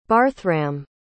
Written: barthram

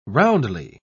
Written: roundly

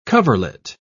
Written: coverlet

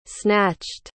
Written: snatched

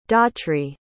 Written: daughtry

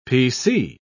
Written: pc

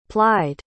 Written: plied

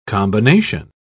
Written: combination

